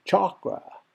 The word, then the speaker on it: chakra: First of all, you're pronouncing it well because a lot of people say chakra. And if you say chakra, that's fine. But just so you know, just because 90% of the people say chakra doesn't make it right. The Sanskrit pronunciation, the original language that it comes from is chakra. And it's actually a cha-a. But I say chakra to kind of split the difference.